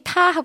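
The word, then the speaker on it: 타 (他): 똑같이 타 하고 발음해 주시면 됩니다. 이게 일성, 높은 음에서 평평하게 발음해 주시면 되고요.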